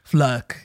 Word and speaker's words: Flake: F-L-A-K-E, but it's pronounced Fluck.